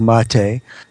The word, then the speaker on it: mate: The pronunciation apparently is mate from the Spanish.